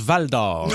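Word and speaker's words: Val-d'Or: Ben Vince, je veux pas te reprendre, loin de moi de l'idée, là, mais tu parlais de bol d'or. Je pense que ça se prononce Val d'or.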